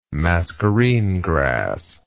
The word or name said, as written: mascarene grass